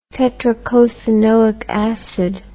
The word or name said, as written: tetracosanoic acid